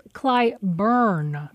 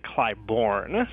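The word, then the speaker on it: Clybourn: decided to pronounce Clyburn. – But it was brought to our attention after the initial recording was done that local Chicagoans, myself included, typically pronounce it Clyburn.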